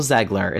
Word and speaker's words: Zegler: And I cannot believe that Rachel Zegler, it's pronounced Zegler, right? – Zegler, yeah.